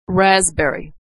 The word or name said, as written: raspberry